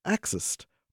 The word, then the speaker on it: Xist: In this recently published paper in the journal Cell, researchers present new evidence that a molecule called Xist, pronounced like the word exist, is found only in women and is a major culprit in these diseases.